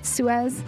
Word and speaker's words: Suez: Did I say that right, Suez? – Yes, you did.